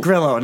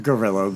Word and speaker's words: Grillo: And, Grillo, not Gorillo, but Grillo.